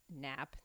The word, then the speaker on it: Knapp: Or sorry, why did I say Cap? That's dumb. Ernie Knapp, K-N-A-P-P. I do know that a K is silent. Thank you very much. And knife and other things are silent.